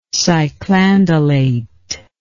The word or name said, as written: cyclandelate